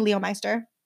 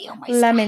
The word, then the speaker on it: Leominster: I always say Leomeister. – Leomeister? That's not even. – That's not even how it's like pronounced or spelled. – That's definitely like a cartoon character in a movie. Like maybe, but it's pronounced Leominster.